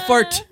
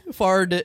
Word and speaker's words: Fard: No, not fart. Fard. It's spelled F-A-R-D.